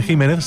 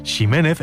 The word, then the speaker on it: Ximénez: Así, por ejemplo, la variedad de una uva conocida como Pedro Jiménez se pronuncia así, aunque ese Jiménez se siga escribiendo con X, si bien también se escucha mucho decir Ximénez e incluso Ximénez cuando se pierde ya la referencia de su pronunciación original.